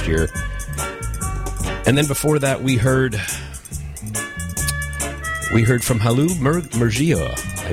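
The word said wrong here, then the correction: Hailu Mergia: I'm not pronouncing that correctly. I apologize for that. It was Halu Mergia and the Dalak Band.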